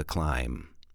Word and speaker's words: climb: It's not a hill, it's a mountain as you start out the climb. Climb, climb, climb, climb, climb. – Wait a second, climb. How do you spell the word climb? C L I M B. Remember, the B is silent.